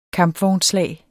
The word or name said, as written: kampvognsslag